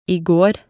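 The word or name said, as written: i går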